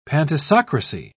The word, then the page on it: pantisocracy: British English